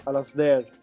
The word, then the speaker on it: Alasdair: Il film è tratto dall'omonimo romanzo scritto da Alasdair Gray, credo si dica Alasdair e di Glasgow, correggetemi sulla pronuncia.